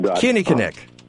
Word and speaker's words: Kinnikinnick: Kinnikinnick. So emphasis on the first syllable.